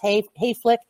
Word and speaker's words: Hayflick: And if your listeners really want a really, really interesting discussion about it, it's Dr. Hayflick, and it's spelled just like it sounds, Hayflick, H-A-Y-F-L-I-C-K.